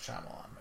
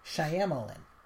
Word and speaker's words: Shyamalan: because it wasn't a Night Shyamalan movie. – Yeah, it's Shyamalan, by the way.